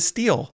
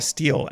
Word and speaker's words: Stihl: And I bought a Steel and in my brain I still had it. I think what's wild about Steel is that it's spelled STIHL and not like steel.